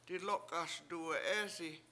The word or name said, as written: diLOk7ASduwe7EEsi